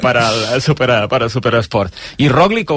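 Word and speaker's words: Roglič: Mira, ya que te tengo, Roglic, tampoco se pronuncia Roglic, ¿cómo lo tenemos que pronunciar? – Roglic. – Roglic, ah, sí, bien. Este sí.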